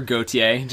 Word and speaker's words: Gautier: I'd prefer Gautier. Just like the musical artist.